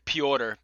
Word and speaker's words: Piotr: Aljamain, but is it Piotr? – Piotr.